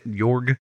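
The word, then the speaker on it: Jörg: It's the O with the umlaut. York. – York. – Jorg. – Okay. Jorg.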